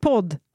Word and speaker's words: pod: Alltså det, det, det uttalas ju podd, men det stavas ju pod. – Mm. Så att man inte tror att podd med två D, utan ett D.